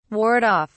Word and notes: ward off: British English

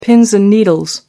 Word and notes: pins and needles: American English

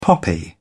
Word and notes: poppy: British English

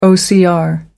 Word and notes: ocr: American English

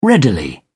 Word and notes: readily: British English

